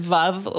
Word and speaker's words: Veuve: Oh, V-E-U-V-E. I still don't know how to say that. – Clico or whatever. – Yeah, you pronounce it Vuv like love.